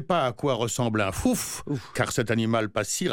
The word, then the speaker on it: fouf: Eh bien, l'animal du mardi, c'est le fouf. – Pardon? – Le fouf. Ouais. Ça s'écrit comme ça se prononce, 3F, O-U, 3F.